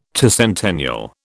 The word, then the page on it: tercentennial: British English